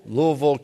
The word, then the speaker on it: Louisville: You know, that's how they say it, Louisville, Kentucky.